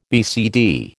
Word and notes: BCD: American English

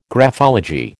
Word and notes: graphology: American English

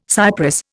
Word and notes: cypress: Indian

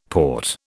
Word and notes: port: British English

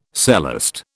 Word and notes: celeste: British English

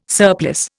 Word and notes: surplice: Indian

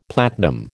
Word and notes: platinum: American English

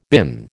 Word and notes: been: American English

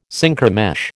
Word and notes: Synchromesh: American English

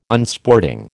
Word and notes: Unsporting: American English

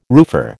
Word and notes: roofer: American English

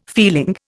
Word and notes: feeling: Indian

